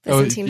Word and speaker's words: Byzantine: And he, you know, he came up with, so that's called Byzantine fault tolerance. Am I saying this right? – Yeah. – Yeah. – Byzantine fault tolerance.